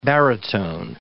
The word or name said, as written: baritone